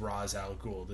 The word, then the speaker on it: Ra's al Ghul: The correct pronunciation is Ra's al Ghul, the demon's interesting.